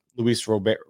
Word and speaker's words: Luis Robert: Luis Robert is how you pronounce it. Luis Robert is how you pronounce it, I believe.